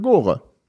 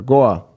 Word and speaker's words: Gore: Jetzt haben wir einen gemeinsamen Freund, der arbeitet jetzt nun zufällig für Gore oder Gore, wie du es aussprechen willst.